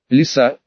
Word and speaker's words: Лиса: Лиса, он говорит лиса с ударением на первом слоге, и это правильно, а почему ты не позволяешь себя фотографировать?